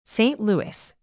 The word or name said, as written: St Louis